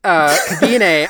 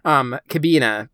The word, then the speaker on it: Kabine: And then Kabine, I think is how they say it in Japanese. In German, it would be Kabina, but I think the Japanese, they'll pronounce it Kabine, like in the anime.